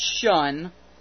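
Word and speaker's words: Ssion: It's called, they're pronounced Shun, but it's S-S-I-O-N if you want to search for it, and of course we'll put the link up.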